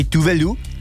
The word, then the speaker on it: Tove Lo: Ano, teďka, když se tady jsme tady řešili tu výslovnost těch švédských jmen, tak já jsem slyšel, že se říká i Tuvelu. – Tuvelu? – Ale Tuvelu, a to je asi ve švédštině, ale my říkáme Tove Lo, protože samozřejmě používáme tu anglickou deskripci.